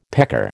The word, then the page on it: pecker: American English